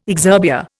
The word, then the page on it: exurbia: Indian